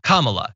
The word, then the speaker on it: Kamala: said her name is pronounced comma, like the punctuation mark, la, Kamala.